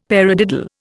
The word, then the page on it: paradiddle: Indian